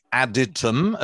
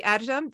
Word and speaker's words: Adytum: never done a full thing on Bota or Builders of the Adytum, as we've learned to pronounce it. – Yeah. Yeah, no, Builders of the Adytum.